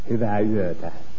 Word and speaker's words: Hyvää yötä: Hyvää yötä.